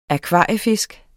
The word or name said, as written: akvariefisk